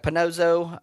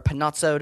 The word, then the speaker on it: Panozzo: Chuck Panozzo, or Panazzo, depending on how you pronounce it.